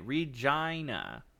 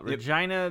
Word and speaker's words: Regina: Regina, Canada, pronounced a long I. Regina. Yeah, got it. Regina, yep. The city that rhymes with fun.